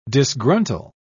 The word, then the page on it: disgruntle: British English